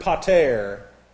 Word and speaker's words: pater: What shall we say then as that Abraham, our father, and here is the little Greek word pater, P-A-T-E-R, pater, P-A-T-E-R, pater. And pater, I use in your class notes that eight because that's an ada and it gets a long A sound where I went to school. I say that because other schools pronounce it different ways.